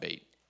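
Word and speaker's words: bait (בית): Now, the bait, that is a bait, pronounced bait.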